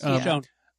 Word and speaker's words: Michonne: And the question that I'm asking is, like, is it going to be the Michonne, is that how you pronounce it? – Yes, Michonne.